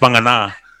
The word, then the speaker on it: Vagana: So Wangana, so it's pronounced Wangana, but there's a little segment he was filmed doing.